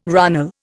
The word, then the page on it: runnel: Indian